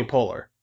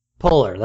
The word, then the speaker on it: Poehler: Like, I wanted, like, Tina Fey and Amy Fowler, and you know. – Right. – But they wouldn't cast them. – You mean Amy Poehler? – Poehler, that's how you say it.